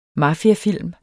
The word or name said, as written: mafiafilm